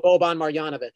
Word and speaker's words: Boban Marjanovic: Boban Marjanovic.